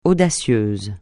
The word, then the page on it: audacieux: francais